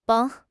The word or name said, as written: 甭